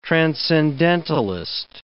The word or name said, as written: transcendentalist